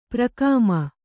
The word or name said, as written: ಪುರೋಗಾಮಿ